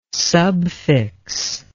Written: subfix

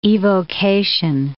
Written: evocation

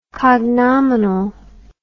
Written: cognominally

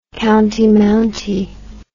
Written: county mounty

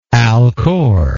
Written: alcor

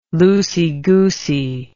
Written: loosey-goosey

